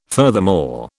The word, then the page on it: furthermore: British English